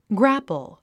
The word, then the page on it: grapple: grǽpl; グラップゥ